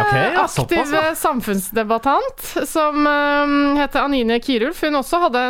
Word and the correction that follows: Kjerulf: en Kjærulf. Kjærulf, sorry. Mm. Kjærulf, da. Men det staves Kjerulf.